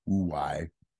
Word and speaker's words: Huawei: H-U-A-W-E-I. Huawei.